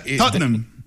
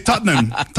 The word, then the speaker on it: Tottenham: Enséñanos, porque es que esa Tottenham. – Tottenham. – Son dos sílabas. – Ajá. Es difícil, ¿no? – Nosotros los británicos. Pero... – ¿Solo dos sílabas? – Sí, Tottenham.